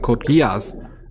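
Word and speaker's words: Code Geass: Das ist Code, Code Gies ist das, was Deutsche immer sagen. Code Gias ist es richtig.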